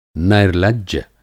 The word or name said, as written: नैर्लज्य